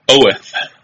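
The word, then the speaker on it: Oeth: His name is Greg Oath, spelled like oath, only with an E instead of an A.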